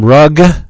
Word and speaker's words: Rugg: Jim Nelson, Jim Rugg, rhymes with Jug.